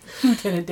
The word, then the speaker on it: lieutenant: Well, it was discovered by his friend, Lieutenant Moxie. – Yeah. – Lieutenant Dan. – And I debated with Dave whether I should say lieutenant or whether I should say it properly as lieutenant.